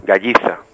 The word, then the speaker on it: Galliza: Galliza. – Galliza. – Que es con dos L's y con Z.